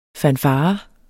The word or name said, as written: fanfare